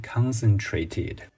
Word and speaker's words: concentrated: 同时要注意喽，它的重音又回到了动词时候的那个重音，变成 concentrated。